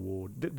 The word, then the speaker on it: Wardh: Now, it is pronounced Wardh, isn't it? – Yes. – Wardh. – The H is silent.